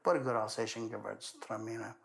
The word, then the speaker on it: Gewürztraminer: Okay. Say that again. – Gewürztraminer. – Gewürztraminer. Yes. Did I say that right? Yeah.